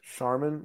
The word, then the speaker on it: Charmin: Charmin, like the toilet paper? – I thought the CH was just an H. – No, it's not a silent C.